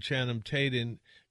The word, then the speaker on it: Channing Tatum: That is a trailer for White House Down, Jamie Foxx and Channing Tatum. How do I say his name? – Channing Tatum.